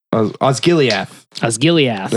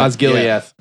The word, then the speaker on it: Osgiliath: Osgiliath. – That's how they say it. Osgiliath.